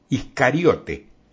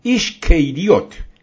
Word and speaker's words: Iscariote: Iscariote, tal como lo pronunciamos y escribimos nosotros, pero que en realidad es Ishkeiriot, Ishkeiriot, que significa hombre de Keriot.